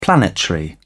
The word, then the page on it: planetary: British English